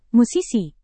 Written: musisi

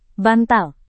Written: bantal

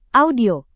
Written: audio

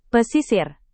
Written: pesisir

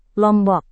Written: lombok

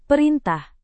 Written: perintah

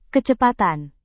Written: kecepatan

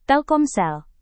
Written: telkomsel

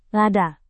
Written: lada

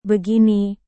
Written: begini

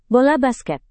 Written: bola basket

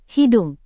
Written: hidung

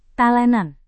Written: talenan

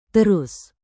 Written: terus

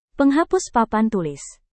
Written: penghapus papan tulis

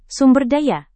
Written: sumberdaya

